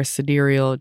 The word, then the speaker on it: sidereal: You can pull your tropical birth chart and your sidereal chart. Did I say it right? Yes.